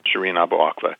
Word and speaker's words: Shireen Abu Akleh: But first, can we talk about Shireen Abu Akleh? Is that how you say it? – Yeah, that's. – Okay. – I don't speak Arabic, but that's how I've learned to pronounce it. Uh-huh.